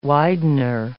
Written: widener

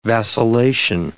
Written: vacillation

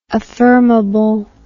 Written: affirmably

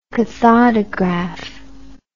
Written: cathodograph

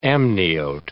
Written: amniote

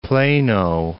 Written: Plano

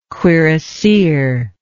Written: cuirassier

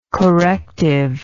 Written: correctively

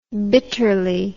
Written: bitterly

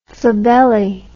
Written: flabelli

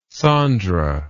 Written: Sondra

